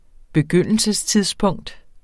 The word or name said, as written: begyndelsestidspunkt